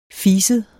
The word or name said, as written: fiset